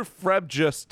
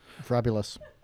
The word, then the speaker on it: frabjous: It has a J in it. – Yeah. – Soft Fragulous. – J. – Soft J. – Fabulous J. Kalu Kalei, he chortled in his joy. – J is sometimes a Y.